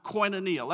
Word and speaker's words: koinonia: It's pronounced koinonia.